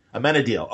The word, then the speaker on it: Amenadiel: Oh, Amenadiel? – So say it for me. Amenadiel, okay.